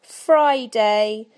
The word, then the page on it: friday: British English